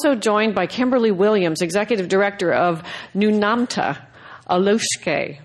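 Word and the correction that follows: Aulukestai: Have I said that right? – Close. – Alukeste. Alukeste. Alukeste.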